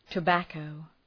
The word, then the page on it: tobacco: tə’bækəʋ